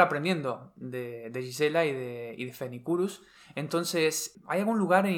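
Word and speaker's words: Phoenicurus: pero si buscáis este nombre Fenicurus,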